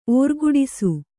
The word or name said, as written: ಓರ್ಗುಡಿಸು